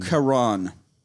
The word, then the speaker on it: Charon: Charon. It's C-H-A-R, or sometimes it's K-H-A-R, so it's a hard K sound.